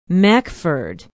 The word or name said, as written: mackford